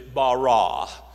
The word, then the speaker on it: bara: Now the word "created" in the Hebrew language is bara. Let me spell it, B-A-R-A. It's the way it's spelled, bara.